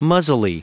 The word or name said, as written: muzzily